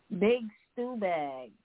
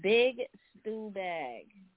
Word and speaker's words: Bigstubag: Reason why it was a little questionable is there's no spaces. And it's spelled B-I-G-S-T-U-B-A-G. So big stew bag. Say it all together.